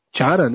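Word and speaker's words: Chará: que é o Xará, Chara, né, na verdade, corrigindo aqui a, a pronúncia, e o Théo Gutierrez, um veterano bem conhecido de todos.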